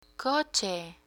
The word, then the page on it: coche: spanish